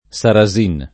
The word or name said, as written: Sarasin